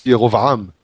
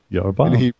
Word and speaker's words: Jeroboam: And with Jeroboam, I kept on saying Jeroboam because it's Yerovam. – Yerovam.